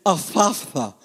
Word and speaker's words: Ephphatha: This is one of those words that it's impossible for English speaking people to pronounce, but it's Ephphatha.